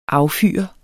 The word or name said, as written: affyre